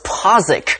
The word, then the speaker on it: Postwick: The small town of Postwick is pronounced Posick.